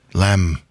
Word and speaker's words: lamb: The weird thing about that word is it has a B at the end in English, but it's pronounced the same as the German lamb.